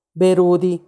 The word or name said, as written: berudi